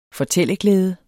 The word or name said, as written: fortælleglæde